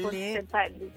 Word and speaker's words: Bontempelli: Bontempelli. – No, anche detto giusto al primo colpo.